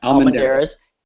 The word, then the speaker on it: Almendarez: J-E-R-R-Y Almendares. Probably butchered that, and I apologize. – Almendares, yeah. – Almendares. A-L-M-E-N-D-A-R-E-Z.